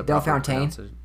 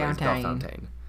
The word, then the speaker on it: Bellefontaine: The correct way is Bellefontaine. – Yes, that's the correct way. Bellefontaine.